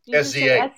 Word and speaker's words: SZA: SZA? – SZA.